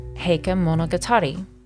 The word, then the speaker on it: Heike Monogatari: The movie is pronounced Heike Story, or Heike Monogatari.